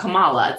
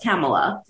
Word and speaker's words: Kamala: It's Kamala. It's not Kamala. It's Kamala.